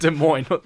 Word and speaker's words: Des Moines: Des Moines, Iowa. – Des Moines. – Des Moines. – Des Moines. – Yeah. Okay, fair enough. I got it fairly right. – That's how it's pronounced, Des Moines.